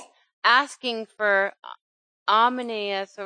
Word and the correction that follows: Onesimus: or how do you say his name? – Yeah, Onesimus, yes.